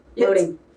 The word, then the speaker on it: Loehding: Like, no, it's funny because they'll be like, Loading. I'm like, Loading. It's just a long O. Loading.